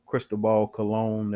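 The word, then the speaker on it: Cristóbal Colón: You know, Cristobal Colon. That's how you say his name.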